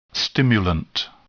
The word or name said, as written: stimulant